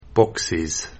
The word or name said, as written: Boxes